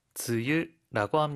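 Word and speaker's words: 즈유라고 (つゆ): 즈유라고 합니다.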